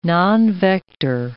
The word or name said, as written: nonvector